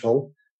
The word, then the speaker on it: Hull: Yeah, well, Yorkshireman, born in the city of Hull, which has got a silent H, Hull.